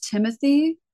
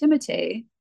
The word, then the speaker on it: Timothée: So my daughter is adamant that it is Timothy, but I showed her a video where he says it's actually pronounced Timothée.